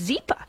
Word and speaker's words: Zippa: Memory loss, which is why we all recommend Zippa, pronounced Zippa. That's happy Z, spelled backwards.